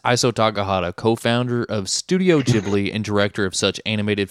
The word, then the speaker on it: Ghibli: Is that right? – Ghibli, yeah. – Ghibli is what we discovered thanks to Wikipedia.